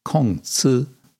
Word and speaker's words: Kongzi: He's called Confucius in from Latin. Um, it's actually Konf, Kongzi in Chinese.